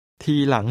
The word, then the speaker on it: ทีหลัง: ทีหลังหรือครับ? ทีหลัง. หลัง. หลัง. ทีหลัง. ทีหลังหรือครับ? – The tones are middle and rising.